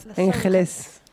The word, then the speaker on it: Los Angeles: Euh, moi, la dernière fois que j'ai dit Los Angeles, on m'a engueulé parce que c'est c'est pas la ville, mais c'est les anges. Et du coup, il faut faire la prononciation espagnole. Los Angeles.